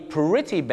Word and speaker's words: pretty: ต้องออกเสียงสระอี pretty bad